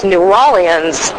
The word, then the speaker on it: New Orleans: That's how you pronounce it, right? – No. – All right, so how do you pronounce it? Let's do it right. – All right, let's do it right. Drum roll, please. It's New Orleans.